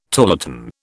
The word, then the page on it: tarlatan: British English